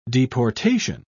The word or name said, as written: deportation